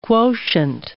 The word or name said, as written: quotient